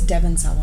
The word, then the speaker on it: Devon Sawa: Kevin Sawa? – His name is Devon Sawa.